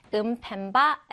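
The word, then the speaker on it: Mpemba: Is the 음펜바 effect, 그쵸? 음펜바라고 발음을 해주시면 됩니다.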